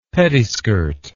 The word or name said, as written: pettiskirt